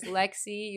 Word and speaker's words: Lexie: My name is Lexie.